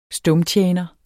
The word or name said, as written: stumtjener